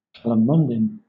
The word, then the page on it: calamondin: British English